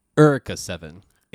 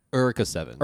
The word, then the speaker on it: Eureka: It's actually Erica 7. It's weird, I know, but like that's how they pronounce it in the show.